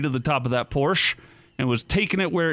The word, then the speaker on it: Porsche: Porsche. – It's pronounced Porsche.